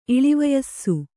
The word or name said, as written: ಇಳಿವಯಸ್ಸು